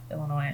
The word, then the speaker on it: Illinois: that is the University of Illinois at Chicago. – Why do you keep saying it wrong? – What? – It's Illinois.